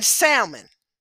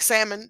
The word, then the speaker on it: Salmon: So Boaz's parents were Rahab the harlot, the Canaanite, and Salmon. And I want to say it like salmon, the fish, you know how we do. But Salmon. Salmon married Rahab the harlot, the Canaanite.